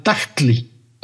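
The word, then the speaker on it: tahtli: Es como un salto glotal, tactli, padre, tactli.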